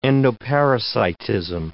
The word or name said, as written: endoparasitism